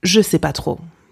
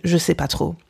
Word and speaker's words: je sais pas trop: Okay, so when you're just unsure, you could say je ne sais pas trop, je ne sais pas trop, but simply pronounced je sais pas trop, je, je sais pas trop.